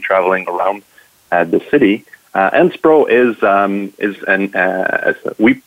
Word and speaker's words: SPRO: Am I pronouncing it right, SPRO? – SPRO, yeah, you're pronouncing it right.